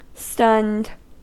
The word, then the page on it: stunned: American English